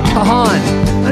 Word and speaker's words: Kahan: Kahan.